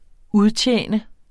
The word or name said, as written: udtjene